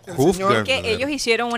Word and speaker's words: Hugh Hefner: Uf Hefner. – Hugh Hefner. Hugh Hefner.